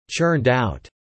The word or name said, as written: churned out